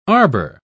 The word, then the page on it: arbor: British English